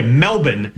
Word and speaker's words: Melbourne: The game is Friday morning in Melbourne time, which, by the way, Melbourne. Is the preferred pronunciation.